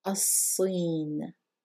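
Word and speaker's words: As-sin (الصين): Again, we're not pronouncing the lam and we are doubling the sad. As-sin. Very good. As-sin is China.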